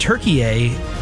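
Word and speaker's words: Türkiye: Yeah, it's Turkey, but everybody's pronouncing it Turkey A. All the announcers, I think that's the proper pronunciation for the country, so I decided to go with that pronunciation.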